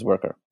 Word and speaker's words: Wercker: I am the CEO and founder of Worker, W-E-R-C-K-E-R. So it's spelled in a sort of European Germanic way, but it's pronounced as Worker.